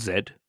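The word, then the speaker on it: Zedd: It's a song by a German DJ called Zed, and that is the name chosen because it is the way that English people pronounce the last letter in the alphabet.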